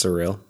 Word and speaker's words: SRIL: It's pronounced surreal, but it's just S R I L.